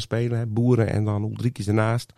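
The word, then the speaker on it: Uldriķis: Uldrix. Ja. Uldrikis. Ja. Ik wou het bijna ja, in... Fries uitspreken.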